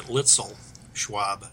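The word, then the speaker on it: Luetzelschwab: for what it's worth, we've kind of Americanized it. I guess we pronounce it Lutzel Schwab, but they even pronounce it different over in Switzerland.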